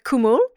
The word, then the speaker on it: cwmwl: So the word cwmwl in Welsh, spelled C-W-M-W-L,